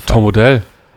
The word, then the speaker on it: Tom Odell: Spricht man den oh, so aus? Tom Odell. – Und jetzt ja.